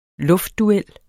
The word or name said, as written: luftduel